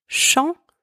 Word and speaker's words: champs: The first one, spelled C-H-A-M-P-S, means field. In this word, the letters C and H together are pronounced sh. The letters A and M together are pronounced on, and the final consonants P and S are silent. Second one, chant, spelled C-H-A-N-T, means song, or like the act of singing.